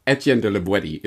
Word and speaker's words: Etienne de La Boétie: Call him whatever you want, but Etienne de La Boétie is apparently the proper pronunciation.